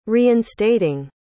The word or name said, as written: reinstating